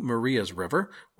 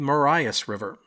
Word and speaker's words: Marias: In this episode, I referred to Maria's River, which is in Montana, as the Marias River, which is wrong. It's Maria's River, and it was just a dorky mistake.